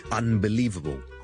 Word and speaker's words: unbelievable: 上一集同大家讲咗 unbelievable，好多时候咧个 U N 咧系会读成好似 U M 咁，咁一个咧系一个 N 音嘅变音嘅情况，系好多时候都会出现嘅。